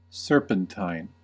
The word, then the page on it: serpentine: American English